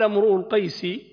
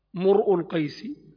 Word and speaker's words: امرؤ القيس: فيها أربع لغات. امرؤ القيس ومرء القيس بضم الميم والهمزة، وامرء بضم الراء والهمزة، وامرأ بفتح الراء وضم الهمزة، ومرء بفتح الميم وضم الهمزة.